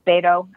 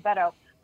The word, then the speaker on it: Beto: Beto? I always pronounce it wrong. – Beto.